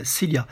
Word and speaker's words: Silja: Alors Cilia, euh, j'espère que je le prononce bien, mais ça s'écrit S-I-L-J-A. Voilà, donc à, prononcer euh, comme s'il y avait un I après le L, Cilia.